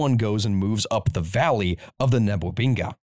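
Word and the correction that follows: Ngwebeni: Negwabeni. I said that completely wrong, different. It's the Negwabeni.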